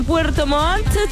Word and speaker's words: Puerto Montt: Mientras que en Puerto Montt, se pronuncia bien, ¿ah? Con 2 T en este día de la radio, en este día del trabajador radial, uno tiene que tener compromiso de hablar bien, de modular.